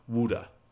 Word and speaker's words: Wude: And so now he has a new path to take, to achieve Wuda, WUDE. I made sure I said it right.